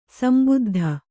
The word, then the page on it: ಸಂಬುದ್ಧಿ: sambuddhi